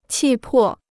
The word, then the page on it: 气魄: qì pò